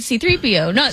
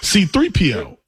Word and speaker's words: C-3PO: C-3PO. Like, I don't know. – Your emphasis is on the C. – C-3PO. – Myron's is on the 3.